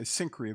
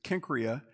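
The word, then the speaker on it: Cenchreae: We tend to say Cenchrea, but it's Kenchrea, a seaport very near Corinth.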